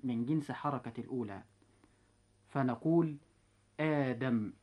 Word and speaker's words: آدم: لاحظتم إخوة الإسلام أن كلمة آدم وكلمة إيمانا، لو قلنا أأدم وإيمانا لأصبح النطق صعبا، ولكن التخفيف جاء حتى يكون هناك سهولة في النطق، فنقول آدم وإيمانا.